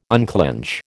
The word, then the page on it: unclinch: American English